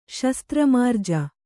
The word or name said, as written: ಶಸ್ತ್ರಮಾರ್ಜ